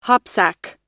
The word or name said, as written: hopsack